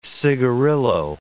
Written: cigarillo

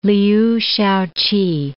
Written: Liu Shao-ch'i

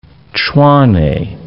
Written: Tshwane

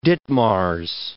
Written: Ditmars

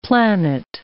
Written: planet

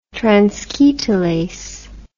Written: transketolase

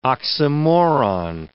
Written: oxymoron

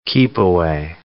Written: keep-away

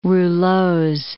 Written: rouleaux

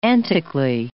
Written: antically

